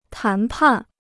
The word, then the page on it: 谈判: tán pàn